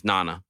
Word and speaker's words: Nana: Like, you got to understand me, my name itself here, if I was a sensitive person, my proper pronunciation, my name is Nana, okay?